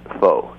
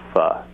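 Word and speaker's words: phở: And I should caution you that after I've been calling myself that for a few years, someone Vietnamese filled me in that it's not even pronounced pho. It's actually pronounced pho in Vietnamese.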